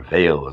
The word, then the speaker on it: Weil: Two good contemporary examples of that are Simone Weil, or Veil, as I'm told her name is properly pronounced, who was a 20th century French mystic.